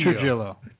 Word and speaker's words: Trujillo: Trujillo.